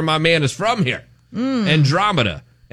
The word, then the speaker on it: Andromeda: Is that it? Andromeda? Did I say it right? – Yeah, Andromeda.